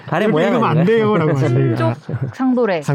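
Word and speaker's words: 친족상도례: 저희가 한숨을 이래 피디님이 저희가 오늘 친족 상도래 친족 상도래라고 읽는 거죠? – 예. 그렇죠. 네, 맞습니다. 네. – 친족상도래. 친족상 네. 도래 아닙니다.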